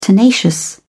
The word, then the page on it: tenacious: British English